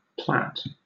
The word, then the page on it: plat: British English